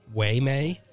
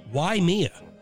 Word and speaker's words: Waimea: It's uh, it's spelled uh, W A I M E A, and I thought that was like Waymay, but it's pronounced Why Mia, which is kind of funny because I'm doing all the mob stuff and it's like, oh, why Mia?